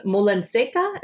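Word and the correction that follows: Molinaseca: is that pronounced correctly? – Molina Seca, yeah.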